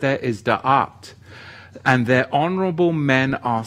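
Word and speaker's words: Da'at (דעת): Da'at, it's spelt Dalet Ayin Tav, and it is a covenant term, whereas the other Hebrew word to know is Yada.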